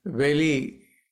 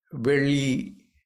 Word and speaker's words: Veli: The second one also has two syllables. Veli. Veli. V-E-L-I.